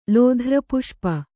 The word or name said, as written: ಲೋಧ್ರಪುಷ್ಪ